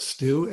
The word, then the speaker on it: Stu: I just need to know how to spell my name, and I'll spell it Stu, S-T-U, and then Heinecke, H-E-I-N-E-C-K-E.